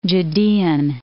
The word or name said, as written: Judean